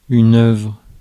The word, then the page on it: book: /bʊk/